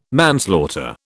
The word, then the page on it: manslaughter: British English